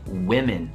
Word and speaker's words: women: però la pronuncia per uno è woman women quindi la prima vocale nel plurale è tipo e